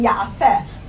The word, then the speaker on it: ya'as: Normally it's actually written ya'aseh, if you have a parchment Megillah, but it's pronounced ya'as Hashem imachem chesed.